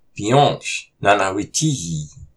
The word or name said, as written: Piyôsh nánáwihtiyi!